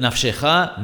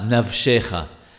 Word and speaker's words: Nafshecha: For instance, those who pronounce the letter B without a daresh as a V must be particular not to pronounce it in Ve'ahabta as Ahafta, and instead of Nafshecha, Navshecha.